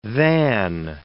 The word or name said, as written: than